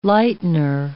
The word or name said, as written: lightener